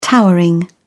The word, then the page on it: towering: British English